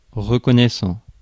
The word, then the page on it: reconnaissant: /ʁə.kɔ.nɛ.sɑ̃/; France